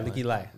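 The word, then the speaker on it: Leikeli: I don't know if I'm saying her name right, but Lakey Lee? – Leaky Lai? – Yeah, Leaky Lai.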